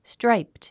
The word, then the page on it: striped: stráipt; ストラァイプド